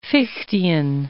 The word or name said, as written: Fichtean